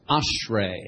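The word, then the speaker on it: ashrei (אשרי): This word is pronounced ashrei. Everybody say ashrei.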